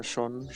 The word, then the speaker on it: Sean: ¿Se pronuncia? Sí. Pues Sean, ¿no? – Sean, Sean Connery.